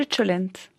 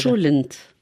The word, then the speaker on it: cholent: Et le cholent. Est-ce que je le prononce correctement? – Le cholent. – Cholent.